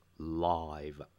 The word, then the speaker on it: live: 这里的 live 是一个形容词，就是直播的，它其实就是 live 住的那个动词，只是当动词的时候我们念 live 住或活着，而当形容词的时候要念成 live。